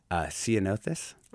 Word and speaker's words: Ceanothus: Ceanothus? Mm-hmm. Yeah. Did I say, get it right? – That's right.